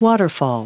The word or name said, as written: waterfall